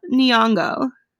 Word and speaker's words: Nyong'o: You were saying like Lupita Nana. – No, I always get too self-conscious when I can't pronounce her name, then I just slew it further. – Lupita Nyong'o.